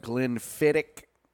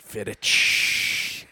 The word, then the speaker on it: Glenfiddich: They got Glenlivet, they got Macallan, they got Glenfiddich. – Ooh, is that how it's pronounced? – I would think so. – I always use the ch. – Fiddich.